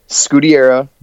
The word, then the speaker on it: Scuderia: Scuderia, yes.